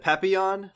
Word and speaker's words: Papillon: Isn't it Papillon? Like, the French word for yeah. butterfly? – Oh, okay. Papillon.